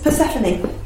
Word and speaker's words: Persephone: No, you said that wrong. Yes, Persephone.